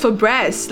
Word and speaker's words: Febreze: It is F-E-B-R, a single E, Z-E. How though? – Febreze. – Exactly, that is Febreze.